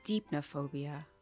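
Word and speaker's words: Deipnophobia: Depnophobia? – Deepnophobia. – It was right there. – You were so close. – I said depnophobia? – Mm-hmm. – Yeah. – Yeah. – Long E. – Long E. – Yeah. Ah, hate those. Longies.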